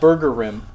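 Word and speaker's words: Burgerim: It's pronounced Burger Rim is how it's pronounced.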